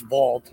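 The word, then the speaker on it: Vauld: Nexo is looking to acquire Vlad. I know I pronounced that incorrectly. So please, why don't you pronounce it correctly for me and give me your thoughts? – So I think it's Vault.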